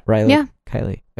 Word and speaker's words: Rilo Kiley: Yeah, Rilo Kiley.